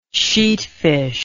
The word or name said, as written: sheatfish